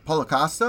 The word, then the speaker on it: Costa: He will box me because he was fighting with uh, oh he was boxing with Paul Costa. Is that is that how I pronounce it? Costa. Paulo Costa.